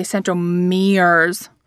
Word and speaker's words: centromeres: In my head, to keep them straight, I do say centromeres, putting the accent on the M in the middle of the word.